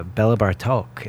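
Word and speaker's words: Béla Bartók: Um, I want to take a break and uh, check out uh, Bela Bartok.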